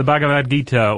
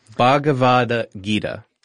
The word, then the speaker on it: Bhagavad Gita: Yes, the Bhagavad Gita, or, you know, you can just call it the Gita. – I always say it wrong. – Yeah. – Bhagavad Gita.